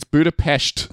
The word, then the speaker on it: Budapest: You still can't pronounce it. It's Budapest. You never put the T on the end.